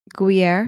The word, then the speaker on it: Gruyere: Say it. Pronounce it. – Gruyere. – There you go. You got it.